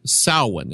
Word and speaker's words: Samhain: It took me years to be able to pronounce that right, because it's spelled Samhain. And I thought, well, who is Samhain? I don't know who that is. Well, they pronounce it Samhain for some reason.